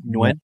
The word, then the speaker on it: Nguyen: Dustin Nguyen.